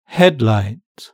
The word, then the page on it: headlight: British English